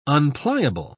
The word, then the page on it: unpliable: British English